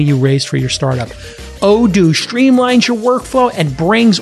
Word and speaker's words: Odoo: Well, Odoo is here to help. That's spelled O-D-O-O.